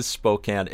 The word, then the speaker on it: Spokane: It is Spokane.